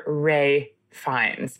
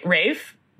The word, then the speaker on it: Ralph Fiennes: John Leguizamo was a delight, but really the highlight of the film for me, everyone, was Mr. Ray Fiennes. Pronounced Rafe, not Ralph. Rafe. I had a fist fight with someone the other day about this at work. They said it's Ralph Fiennes.